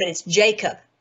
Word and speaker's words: Jakob: I think he called it Hakab. And I'm like, wait a minute, Hakab, that is the way that it is. I think it says it's pronounced in Hebrew, but it's Jakob.